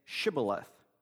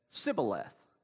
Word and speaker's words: Shibboleth: And when he said, "No," they said to him, "Then say, Shibboleth." And he said, "Sibboleth," for he could not pronounce it right.